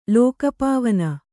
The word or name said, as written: ಲೋಕಪಾವನೆ